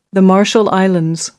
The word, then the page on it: marshall islands: American English